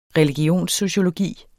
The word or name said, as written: religionssociologi